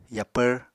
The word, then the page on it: yapvr: Apatani